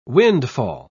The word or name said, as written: windfall